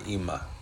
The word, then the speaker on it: Ima: it's E M M A but they call her Ima.